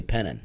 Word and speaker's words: Penton: The town he lives in is spelled Penton, P-E-N-T-O-N, but they say Pennon. – Sounds perfect. – Pennon.